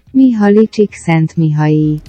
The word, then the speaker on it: Mihaly Csikszentmihalyi: A pronúncia do nome é difícil mesmo, é um nome húngaro e se fala assim. – Mihaly Csikszentmihalyi.